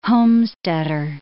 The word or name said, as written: homesteader